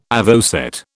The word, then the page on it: avocet: British English